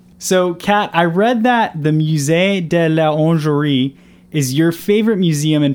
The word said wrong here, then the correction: Musée de l'Orangerie: Yeah, so the Musée de l'Orangerie, it's really hard to pronounce, I guess, as Americans here, but um, I absolutely love it.